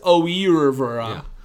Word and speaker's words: oeuvre: In his oeuvre. Yeah. That's how you pronounce it.